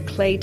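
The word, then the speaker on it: Clé: There's a tile called it's called the clay tile, so it's spelled C L E with an accent on the E, and it's a company out of the U.S., and they do different, basically different variations on the subway tile.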